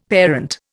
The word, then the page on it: parent: Indian